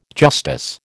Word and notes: justice: American English

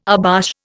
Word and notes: abash: Indian

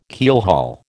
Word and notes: keelhaul: American English

